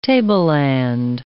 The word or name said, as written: tableland